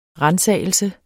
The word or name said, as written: ransagelse